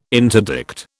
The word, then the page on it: interdict: British English